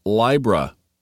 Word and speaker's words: Libra: And in Latin, it is pronounced Libra. So the ancient original pronunciation is Libra.